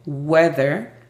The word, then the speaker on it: weather: La pronunciación fonética es J-A-O, how, is, E-S, D-A, the, U-E-D-R, weather, T-U-D-E-I, today.